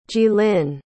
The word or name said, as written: jilin